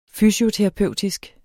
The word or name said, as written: fysioterapeutisk